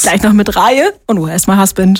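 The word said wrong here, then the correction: Raye: Reihe, so spricht man sie aus, ne? – Scheiße, ist ja noch nicht mal aufgefallen. – Nein. – Ray, nein. Du hast stumm vorgelesen. Ray.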